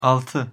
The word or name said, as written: alti